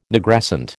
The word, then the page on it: Nigrescent: American English